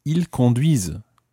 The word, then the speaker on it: conduisent: And ils conduisent. – Ils conduisent. – So that's ending in, well, the whole verb is spelled C-O-N-D-U-I-S-E-N-T. And that's your silent E-N-T ending.